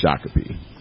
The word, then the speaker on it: Shakopee: And he was reading one in class, and he pronounced it Shakopee. – Yeah. – And the rest of us in the class, who are all, you know, most of us are Minnesotans. – Giggling. – So we're just kind of giggling and kind of snickering, and he kind of looked up like, what? And the teacher was like, it's Shakopee.